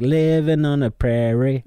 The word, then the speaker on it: prairie: De sier vel prayer. De sier jo prairie. De sier ikke prayer. De sier prairie.